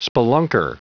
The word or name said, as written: spelunker